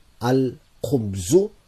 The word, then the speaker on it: Al khubzu: Notice the lam is being pronounced. Al khubzu.